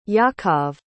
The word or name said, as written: yakov